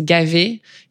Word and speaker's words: Gavet: And then in French, you usually don't pronounce the last letter. So my last name is Gavet. You don't pronounce the T.